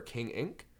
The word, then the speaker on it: King Ink: But I write music under the the name, the moniker King Ink, K-I-N-G space I-N-K, King, it's King Ink.